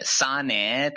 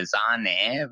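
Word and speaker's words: Sané: آره البته خب این سانه تلفظ اون زبان مادری اسمش سانه بوده بعد همیشه یکی از شوخیایی که توی رادیو اینا با یواخیم لو میکنن میگه خیلی یه جوری قلیزی میگه زانه و زانه تو آلمانی معنیش میشه خامه بعد آره خیلی خلاصه تو رادیو اینا با یواخیم لو شوخی میکنن که اینو بلد نیست درست تلفظ کنه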